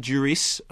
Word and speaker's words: Duris: And so what we have now is this really pretty terrific film with a great central performance by Romain Duris. I think you do pronounce the S.